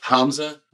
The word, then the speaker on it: Hamzeh: It's pronounced Hamza.